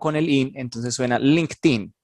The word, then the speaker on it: LinkedIn: La correcta pronunciación es LinkedIn o LinkedIn con una T, porque son dos palabras en inglés. Link y luego la ED que viene pegada se pronuncia como una T después de la K en inglés.